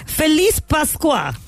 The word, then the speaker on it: Feliz Pascua: Feliz Pascua.